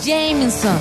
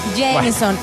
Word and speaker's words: Jameson: El Jameson, pronúncialo bien. – Jameson. – Yeah, baby. – Exactamente. – Ya se le pegó la lengua, ¿no? – No, porque es que así se pronuncia. Jameson.